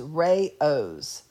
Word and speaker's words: Rao's: It has two-syllable pronunciation. It is Rao's.